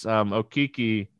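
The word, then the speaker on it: Okeke: I'm gonna go Okoki. I keep saying his name wrong. And I was at the Magic game last night, and I'm still O-Kiki. Saying Okiki.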